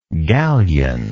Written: galion

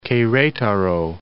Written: Queretaro

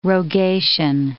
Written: rogation

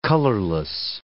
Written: colorless

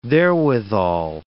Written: therewithal

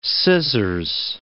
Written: scissors

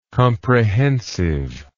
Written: comprehensiveness

